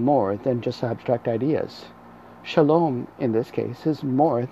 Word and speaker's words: Shalom: Repeat after me, Shalom. You'll spell this in English as S-H-A-L-O-M. Again, that's S-H-A-L-O-M.